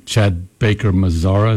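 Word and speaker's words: Chad Baker-Mazara: Chad Baker Mazzara, is that how you say it? – Mm-hmm.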